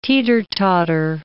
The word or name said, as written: teeter-totter